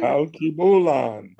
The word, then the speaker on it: Al Kebulan: Did I pronounce that right? – Al Kebulan. – Al Kebulan. – Yes, the accent's on the key.